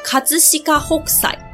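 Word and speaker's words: 葛飾北斎: 日文念作卡兹西卡霍塞。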